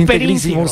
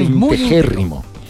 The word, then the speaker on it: integérrimo: No se dice integrísimo, se dice integérrimo.